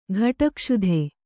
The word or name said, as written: ಕೋಟುಗಸ್ತು